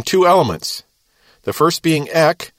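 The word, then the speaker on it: ek (ἐκ): Now that's spelled ek for ek, and kaleo for kaleo. And there's no line over that e, it's the short e.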